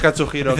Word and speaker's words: Katsuhiro: Es Katsuhiro. – De Katsuhiro, que lo has pronunciado mal.